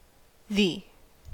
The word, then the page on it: thee: American English